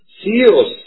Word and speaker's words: سيروس: اما المقوقس الثاني فقد ذكرته المصادر العربية باسم فيرس بالفاء، وكتبها بعضهم قيرس بالقاف، ونختار ان نكتبها كما تنطق وهي سيروس، وهو اسم يوناني.